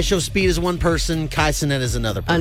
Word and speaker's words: Kai Cenat: Kai Cenat, I think that's how you pronounce his name.